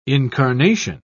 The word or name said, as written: incarnation